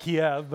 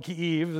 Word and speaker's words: Kyiv: It used to be called Kiev, but I think now it's called Kyiv. This is a more appropriate pronunciation.